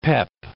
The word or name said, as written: pep